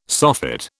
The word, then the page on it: soffit: British English